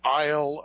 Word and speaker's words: Aisle: Isle of Toys, because I'm 53 years old and I love toys, but it's spelled A-I-S-L-E of toys.